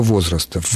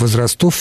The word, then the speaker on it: возрастов: Только возрастов. Возрастов все-таки не годится.